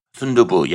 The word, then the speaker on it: Sundubu: Did I say it right? – Sundubu. Yeah, you said it right.